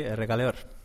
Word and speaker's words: Errekaleor: Nos encontramos en un barrio bastante peculiar de Vitoria-Gasteiz, el barrio de Errekaldeon. No sé si lo pronuncio bien, compañero. – Sí, Errekaldeon.